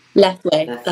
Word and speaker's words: Lethwei: So with the bare knuckle fighting, Lethwei, am I saying it right? – Lethwei, that's correct.